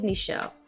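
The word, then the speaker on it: Ne'Chell: Because one, let me just say, my name is Nichelle.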